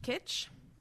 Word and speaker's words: Kitsch: The first time I did it, it's called Keech. No, I'm totally idea. Effing up the, all these names. K-I-T-S-C-H. – Okay. – How would you pronounce Kitch. That? Kitch. Great.